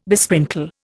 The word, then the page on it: besprinkle: Indian